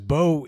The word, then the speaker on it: Beau: My name is Bo. It's not spelled B-O. It's spelled B-E-A-U because of the Cajun influence of my name.